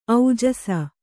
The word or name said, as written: ಔಜಸ